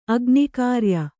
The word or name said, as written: ಅಗ್ನಿಕ್ರಿಯೆ